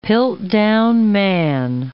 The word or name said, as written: Piltdown man